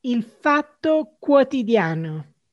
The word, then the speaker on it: Il Fatto Quotidiano: It is Il Fatto Quotidiano.